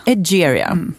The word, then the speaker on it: Egeria: Egeria.